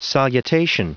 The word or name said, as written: salutation